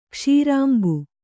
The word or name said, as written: ಕ್ಷೀರಾಂಬು